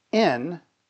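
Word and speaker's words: in (ἐν): So your life, the life that you now live in Christ, is in relationship with Christ in God. Let me throw something at you here. In God, and the Greek word there is in, that's how it's pronounced.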